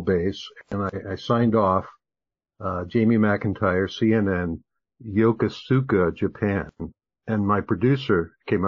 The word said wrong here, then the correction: Yokosuka: up to me and she said, Jamie, that was really good, except that it's pronounced Yokosuka. In Japanese, they don't accent it that way.